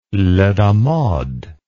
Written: lait d'amandes

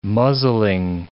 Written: muzzling

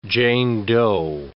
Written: Jane Doe

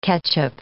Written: ketchup

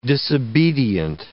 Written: disobedient

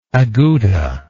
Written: agouta

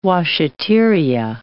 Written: washeteria

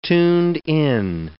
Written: tuned-in

